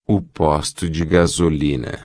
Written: posto de gasolina

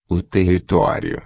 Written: territorio

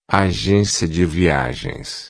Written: agencia de viagens

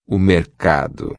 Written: mercado

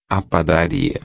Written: padaria

